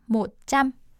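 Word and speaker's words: một trăm: 모짬. – 모짬. – 모짬. – 모짬. – 네. 짬은요. 스펠링이 T-R-A-M. 다가 네. 바가지 씌워주세요. 그렇게 되면은 이제 짬이라고 읽고요. 백이라는 뜻이 됩니다.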